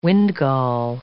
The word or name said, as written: windgall